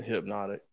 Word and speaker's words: Hpnotiq: Well, flip the N and the O, but basically, it's it, there's no Y in hypnotic.